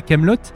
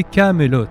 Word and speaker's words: Camelot: C'était pas de la Camelot, c'était le fameux château du roi Arthur. C'était un titre qui s'appelait Camelot.